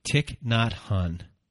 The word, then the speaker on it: Thich Nhat Hanh: So here's a poem that you're going to read by Thich Nhat Hanh. Did I say it correctly? – Yes, we did.